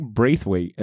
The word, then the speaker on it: Braithwaite: To go back to the creative team real quick, Doug Braithwaite, hopefully I'm saying that right. – Yes.